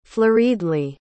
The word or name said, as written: flurriedly